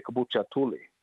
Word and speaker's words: Kabucha Tuli: I know with the outside we say stickball, but when we announce it, we say Kabucha Tuli is how we pronounce it for the tournaments and all.